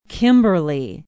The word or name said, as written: kimberly